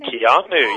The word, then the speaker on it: Keanu: Keanu, yeah. It's in the actor Keanu Reeves.